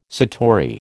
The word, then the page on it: satori: American English